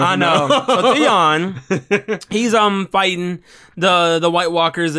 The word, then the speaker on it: Theon: So, Theron. – Theron. – How do you say his actual name now? – Theon.